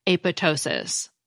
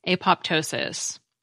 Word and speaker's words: apoptosis: and we usually pronounced the P, apoptosis. Finally, the Oxford English Dictionary shows that in 1994, the esteemed journal Nature sided with the apoptosis pronunciation, calling the silent P, quote, neither correct nor attractive, unquote. Because if I'm reading this right, when the Greek PT combination appears in the middle of a word, the P usually is pronounced.